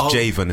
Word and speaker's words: Jayvon: It's Jayvon. It's like Raven with a J.